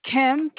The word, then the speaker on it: Kim: Kim, K-I-M.